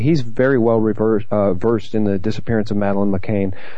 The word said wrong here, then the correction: McCann: McCann, I guess is how you pronounce it. I tend to say McCain.